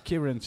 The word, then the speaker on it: Kerrunch: Alors, Crunch, ça s'écrit K-E-R-R-U-N-C-H.